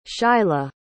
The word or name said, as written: shyla